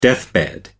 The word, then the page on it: deathbed: American English